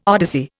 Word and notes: odyssey: Indian

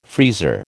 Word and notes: freezer: American English

